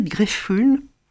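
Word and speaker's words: Greffulhe: là, je vais un peu trop vite parce qu'elle rencontre une comtesse, Elisabeth Greffühl. Oui. Je ne sais pas comment on prononce Greffühl. – Greffühl, qui a été ravissante.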